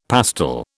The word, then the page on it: pastel: British English